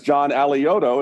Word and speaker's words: John Alioto: Next up is John Alioto.